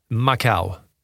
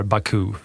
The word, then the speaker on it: Macau: Macau. Sättet som du gärna uttalar Baku, fast det är Macau.